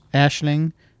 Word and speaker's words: Aisling: Sarah Millican, Aisling, pronounced Ashling, B, Sarah Pascoe, Catherine Ryan, all from Canada, moved to the UK for comedy.